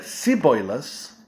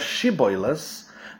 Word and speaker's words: Shibboleth: and it was very difficult for them to say the word, the letter sh, or the sound sh. When they would pronounce it, they would use the word s. So if they said Shibboleth instead of Shibboleth, the soldiers of Yiftach knew straight away that these men were people from Ephraim.